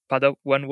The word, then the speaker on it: Padawan: Padawan wallet, is that the right pronunciation? – Star Wars reference. – Yeah. – Yeah, that's right.